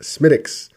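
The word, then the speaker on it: Smithwick's: It's spelled Smithwix, but it's pronounced Smittix.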